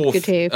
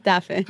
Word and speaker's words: Getafe: I believe it's Getafe. – It is indeed Getafe. – How do you really pronounce it? – Getafe. – Getafe, lovely.